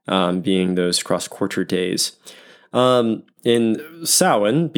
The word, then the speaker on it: Samhain: spelled S-A-M-H-A-I-N, so it looks like Samhain, but apparently it is pronounced Samhain.